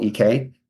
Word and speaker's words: Galek: So if you haven't read this article already, check out by Ed Weiss, Christine with a K, Kristine, Gaelic. Did I say that right? Yep. Galek.